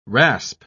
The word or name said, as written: rasp